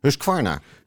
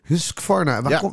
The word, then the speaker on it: Husqvarna: Husqvarna? Husqvarna. Husqvarna.